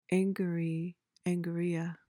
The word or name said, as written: angary